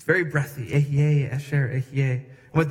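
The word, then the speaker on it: Ehyeh asher ehyeh: I am who I am in Hebrew sounds like this. Ehye asher ehye.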